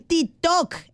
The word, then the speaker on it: TikTok: Eh, el, el, el tiquetó, ah, El... TikTok, el TikTok. ¡Ay, se pronuncias bien.